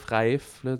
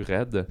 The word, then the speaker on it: Raif: Ben, je dis Raif, Raif, c'est. arabe, fait que je sais pas, prononce de différentes façons parce que cette famille-là maintenant habite au Québec, à Sherbrooke. Et fait les Québécois vont dire Raif, mais les, les autres prononcent un petit peu plus raide. Euh,